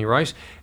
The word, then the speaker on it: Reisz: All right, and that's Tiffany Rice. And by the way, spell her last name because it's not as it is pronounced. – Yeah, it's R-E-I-S-Z. – R-E-I-S-Z. – Yes. – Tiffany Rice.